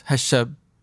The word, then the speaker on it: هالشاب: It's really هذا الشاب, but it gets compressed altogether and goes Hashab. Mm-hmm. So that's how you say it. Min Hashab.